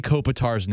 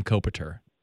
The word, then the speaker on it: Kopitar: She can control the way that she pronounces Anze Kopitar's name, because last night she was calling him Kopitar. – Kopitar. – I can't let that fly. – Multiple times Selke winner, can't even get his name pronounced right.